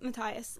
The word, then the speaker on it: Matthias: I have 2 points about Inej and, Matthias, right? – Yeah, Matthias.